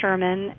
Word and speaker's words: Sherman: So my name is Kara Sherman, and it's spelled K-A-I-R-A, and then last name S-H-E-R-M-A-N.